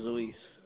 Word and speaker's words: Luis: My name is Luis. – Could you spell that for me? – L-U-I-S.